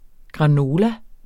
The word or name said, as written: granola